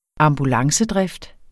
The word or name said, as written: ambulancedrift